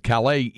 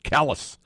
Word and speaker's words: Calais: Somebody said Calais is the proper French pronunciation for Calais, man. Locals pronounce it Calais.